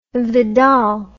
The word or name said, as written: Vidal